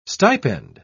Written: stipend

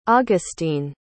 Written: agustine

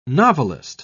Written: novelist

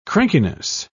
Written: crankiness

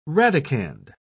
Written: radicand

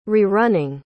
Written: rerunning

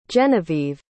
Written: Jenavieve

